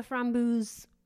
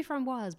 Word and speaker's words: framboise: I didn't like the Framboos glycolic serum, which is the big thing. I think it's someone saying it correctly. It should be framboise, but framboos.